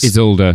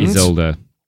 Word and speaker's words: Isolde: I'm going to butcher this. – Isolde. – Oh, thank you. He was thinking of doing Tristan and... – Isolde. – Isolde. Yeah. Good.